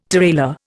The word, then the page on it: tirailleur: Indian